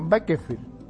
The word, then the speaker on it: Bakersfield: En la ciudad de Bakersfield, se pronuncia así, ¿no? Bakersfield. Ajá.